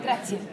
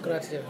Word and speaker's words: Grazie: Grazie. – Grazie, all right.